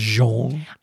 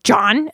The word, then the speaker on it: JonBenét: Is it Jean? – I don't know. Some people pronounce it John, and then some people pronounce it Jean. – Well, I think it is. – I think it's JonBenét.